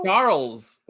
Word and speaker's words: Charles: What's up with the Charles? What's up with the Charles? – It's a French thing, bro. They don't pronounce the end of any of the letters at the end of their words.